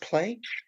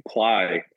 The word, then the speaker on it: Plai: Is it pronounced Play? – Ply, actually. I think it has some meaning in Ukrainian, I believe. – Spelled P-L-A-I.